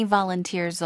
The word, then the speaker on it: volunteers: 이것도 끝에 부분에 강세줄을 좀 길게 끌어야 되거든요. 자원봉사자 발음 도와드릴게요. – Volunteers. – Volunteers. – Volunteers. – 앞에도 살짝 강세 주시고 volunteers. One more time.